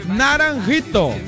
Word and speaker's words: Naranjito: Pega no Se pronuncia assim, ó. Naranjito, ah.